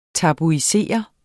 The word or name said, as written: tabuisere